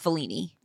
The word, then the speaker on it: Fellini: How do you pronounce Fellini? Brooke. – You got it.